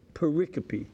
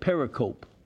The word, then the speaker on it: pericope: Pericope. It's spelled P-E-R-I-C-O-P-E. Some people think it looks like pericope, but it's a Greek word, so you say pericope.